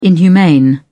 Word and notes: inhumane: British English

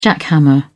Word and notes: jackhammer: British English

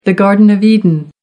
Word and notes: garden of eden: American English

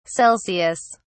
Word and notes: Celsius: British English

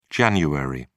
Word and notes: Jan: British English

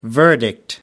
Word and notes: verdict: American English

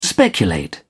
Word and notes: speculate: British English

